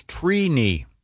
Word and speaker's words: Trini: The correct pronunciation is Trini. – Wow. – Trini. – Like T-R-E-E. – Like weenie. – Yeah, is it phonetically T-R-E-E-K-N-I? – Yeah, it's, phonetically it is T-R-E-E.